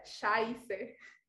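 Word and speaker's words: Scheiße: tiene hay una letra en alemán que parece una B, pero en realidad es, es como un dos S, se pronuncia como dos S. Entonces, en sí, lo estábamos pronunciando mal, o se pronunciaba Chevy eh, y la E y la I se pronuncian I, entonces se pronuncia Shaise.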